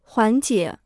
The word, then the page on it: 缓解: huǎn jiě